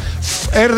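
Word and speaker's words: Fher: Fer con H, ¿no? – Fer. Así se pronuncia, Fer.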